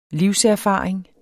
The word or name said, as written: livserfaring